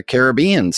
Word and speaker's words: Caribbean: And Walt pronounced it Pirates of the Caribbean, so that's what it was.